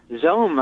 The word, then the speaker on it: XOHM: How do you pronounce that? – Zoom, I think they pronounced it.